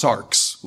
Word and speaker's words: sarx: So there you can see the word, which is pronounced sarx, like a K-S. Sarx, literally, if you just translate it really literally, it means flesh.